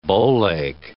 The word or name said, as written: bowleg